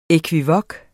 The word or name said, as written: ekvivok